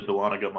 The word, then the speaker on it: Dahlonega: The park opened featuring a number of rides and entertainment, including a dolphin show, and one of the park's current coasters, the Dahlonega Mine Train.